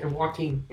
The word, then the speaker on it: Joaquin: Joaquin Phoenix. Am I saying it right now? – Yes, Joaquin. Yeah, Joaquin.